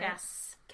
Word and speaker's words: Kas: Kass. – K-A-S.